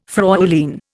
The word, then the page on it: Fräulein: Indian